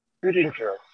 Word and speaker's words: Guttinger: Gutinger. – Can you spell that? – G-U-T-T-I-N-G-E-R.